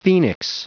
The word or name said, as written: phoenix